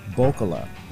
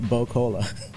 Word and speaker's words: Bokola: There was a cocktail being served yesterday by Halle Pelle that is pronounced Bokola. Mm-hmm. B-O-K-O-L-A. I kept calling it Bo-Cola.